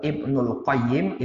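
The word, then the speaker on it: Ibnul Qayyim (ابن القيم): Ibnu Qayyim Ya, cara membacanya ada dua. Ibnul Qayyim ini kalau enggak pakai Al-Jauziyah. Tapi kalau pakai Al-Jauziyah, Ibnu Qayyimil Jauziyah. Al-nya pada Al-Jauziyahnya. Jadi kalau enggak pakai Al-Jauziyah, Ibnul Qayyim.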